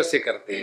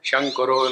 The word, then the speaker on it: संकरो: प्रथम अध्याय में एक श्लोक आता है, संकरो नरकायैव कुलज्ञानां कुलस्य च। सभी बंगालियों ने शिवजी को भेज दिया नरक में, शंकरो नरकायैव। तो जहां हमने एक कहा दूसरे का निर्माण हो गया।